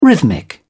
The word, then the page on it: rhythmic: British English